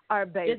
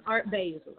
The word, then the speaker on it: Art Basel: Ain't that how you say it? Art Basel? How do you say it? – Art Basel. – Is it Art Basel? – That's how I say it. – It's Art Basel. Okay, so it's not Basel.